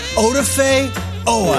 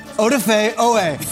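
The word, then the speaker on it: Odafe Oweh: It's not Odafe, it's Odafe. Odafe.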